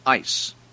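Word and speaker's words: eis (εἰς): The Greek is eis, E-I-S, pronounced eis, or if you have a German pronunciation, ice. Eis.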